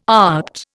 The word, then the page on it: opt: Indian